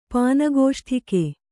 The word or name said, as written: ಪಾನಗೋಷ್ಠಿಕೆ